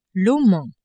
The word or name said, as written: Oman